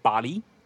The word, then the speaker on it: Bale: Instead, they are lending their recognition to Taiwan's Cedic Bali. Apparently, that's the official Cedic language pronunciation, so I'm calling it that way now. Not Bale, because that's Christian Bale, and that's another movie.